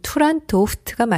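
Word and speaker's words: Turandot: 투란도프트가 맞는 발음이고요. 영어 더터와 비슷하죠? – 네.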